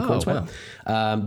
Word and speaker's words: Corenswet: And I happened to watch a movie that included the newly cast Superman, David Corenswet. I don't know how to say his name. – Corenswet.